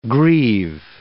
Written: greave

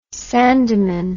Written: sandeman

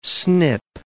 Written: snip

